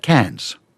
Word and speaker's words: Cannes: Cannes. – Cannes. But okay. English people pronounce that Cairns.